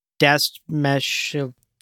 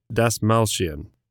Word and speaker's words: Dastmalchian: In the next two shots, we see Piter DeVry University, played by David Dastmalchian. Butchered his name again. – Dastmalchian. Sorry, David.